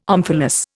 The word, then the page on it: omphalos: Indian